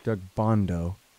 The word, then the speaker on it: Doug Bandow: You know, our friend Doug Bandow always points out, Doug Bondo, is how you say it, not how you spell it.